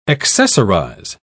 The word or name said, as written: accessorize